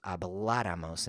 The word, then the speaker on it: habláramos: Nosotros habláramos. And if you think about that word habláramos, it's stressed on the third to the last syllable, habláramos.